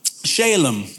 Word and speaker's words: Salem: Okay, so he's also said to be the king of Salem, right? Or Shalem, depending on how you want to pronounce it.